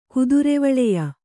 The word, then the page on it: ಕುದುರೆವಳೆಯ: kudurevaḷeya